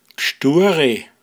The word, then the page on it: a Gseis måchn: Pinzgauer Mundart